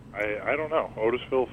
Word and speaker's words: Otisville: Well, the Ramen Boys, a division of the Ramen Gang at Otisville Correctional...